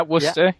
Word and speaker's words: Worcester: And Chris in, I guess it's pronounced Worcester. Is that the name of the town? – Yeah, Worcester. Yeah Worcester.